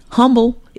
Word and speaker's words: humble: When you can recognize that you've done wrong, and then you can admit it and own it, and then come back humble. Is it humble or humble? – I pronounce my H. Okay. Humble. – I think the H is silent, like salmon.